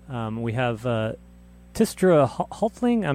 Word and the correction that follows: Tistria Haltling: I'm probably pronouncing your name incorrectly. – Pretty close. It's Tistria Haltling. – Tistria Haltling. Did I get it right that time? – You did.